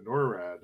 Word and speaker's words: NORAD: NORAD.